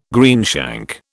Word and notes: greenshank: British English

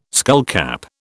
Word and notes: skullcap: British English

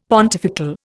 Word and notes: pontifical: Indian

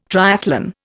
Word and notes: triathlon: Indian

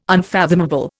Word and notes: unfathomable: Indian

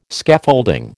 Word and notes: Scaffolding: American English

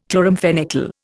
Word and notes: chloramphenicol: Indian

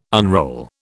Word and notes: unroll: British English

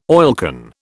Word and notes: oilcan: British English

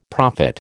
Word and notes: prophet: American English

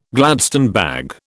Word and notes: Gladstone bag: British English

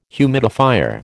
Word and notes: humidifier: American English

